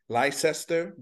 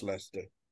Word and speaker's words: Leicester: Look, it looks like Leicester, Leicester, but it's pronounced Leicester.